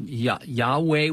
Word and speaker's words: Yahweh: Yahweh would be the pronunciation. It's literally not even any vowels. It's YHWH in Hebrew, which could, it's you know, YHWH is more closer to Yahweh than it would be Jehovah. So they have an old King James translation of Jehovah, but they didn't have the J sound in Hebrew.